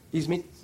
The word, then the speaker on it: Iznik: Iznik.